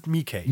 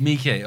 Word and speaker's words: McKay: You put, do you pronounce it McKay or Mikkei? – I pronounce it Mikkei. – Mikkei, okay. – I think that's I think it is Mikkei.